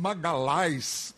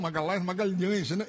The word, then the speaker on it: Magalhães: E o empresário italiano, como na língua italiana você sabe que o LH não é pronunciado, ele disse: Magalhães. Eu digo: não, Magalhães.